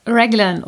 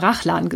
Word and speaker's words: Raglan: Und zwar die Raglan oder Raglan-Konstruktion. Ich habe immer Rachlan gesagt. Das ist natürlich aussprachetechnisch überhaupt nicht richtig.